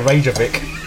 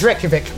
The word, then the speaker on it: Reykjavik: I can't even say Reykjavik. Possibly because it's Reykjavik. Yeah, there you go.